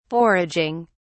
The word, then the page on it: foraging: British English